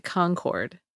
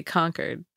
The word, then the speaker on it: Concord: They pronounce it Concord. – Oh, really? – And out here we say Concord. – Like Concord and like Lexington and Concord.